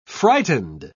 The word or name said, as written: frightened